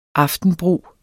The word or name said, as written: aftenbrug